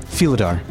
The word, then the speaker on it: Philidar: Philidar? We looked up how to pronounce this. Philidar? – Philidar. – Philidar. – It's like feline. – Because you're feeling it. – From the darkness. – I'm really feeling it. It's like Felis Catus.